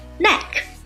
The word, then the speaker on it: knack: Knack, K-N-A-C-K, Knack, 名词，表示诀窍、窍门。这个单词啊，我们注意它的发音，那个 K-N-A-C-K, 第一个 K 是不发音的 Knack。